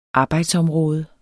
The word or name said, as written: arbejdsområde